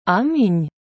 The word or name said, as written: Амінь